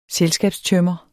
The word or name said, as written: selskabstømmer